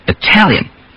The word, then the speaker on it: Italian: The same thing in the words Italy and Italian. Again, we change the first one to a D because it stresses the first syllable, Italy, and we keep the T in the other because the second syllable is stressed, Italian.